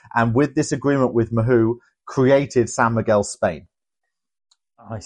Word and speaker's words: Mahou: Yep, Mahou, Mahou, Mahou.